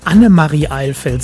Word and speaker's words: Annemarie Eilfeld: Annemarie Eilfeld, so heißt sie richtig, nicht Maria.